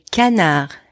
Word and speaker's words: canard: Le canard. Duck. Le canard. And don't pronounce the D at the end.